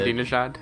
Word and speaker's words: Ahmadinejad: Ahmadinejad or what? I can't pronounce it. – Ahmadinejad.